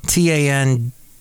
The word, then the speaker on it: TANDM: The next song is by Tandem. Hi. Hey, it says. I'm Maxine from Tandem. That's spelled T-A-N-D-M. Tan-D-M.